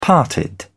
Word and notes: parted: British English

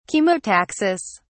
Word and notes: chemotaxis: British English

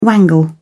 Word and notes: wangle: British English

